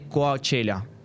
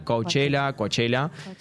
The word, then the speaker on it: Coachella: Y se hizo una noticia muy popular porque, creo que fue ayer, estuvieron tocando en el Festival Californiano de Coachella. Perdón, se lo dije mal. Coachella.